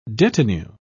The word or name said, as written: detinue